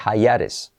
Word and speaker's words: hiatus: Un hiatus se dice hayares. Hayares.